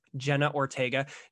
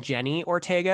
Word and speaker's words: Jenna Ortega: I hear people saying Jenny Ortega, and that drives me crazy because, like, I was questioning myself for a long time. I was like, am I saying it wrong? I'm like, is it Jenny? So I went and checked, and I was like, no, it's Jenna. So no, it's Jenna, our budding scream queen too.